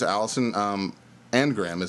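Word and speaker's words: Alison Arngrim: Her name is Allison Angram is the way you pronounce it.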